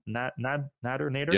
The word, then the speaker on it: Nader: As they release this, there's mounting pressure on Tesla's full self-driving effort because Ralph Nader, is that how you pronounce his name? Nader? – Yep.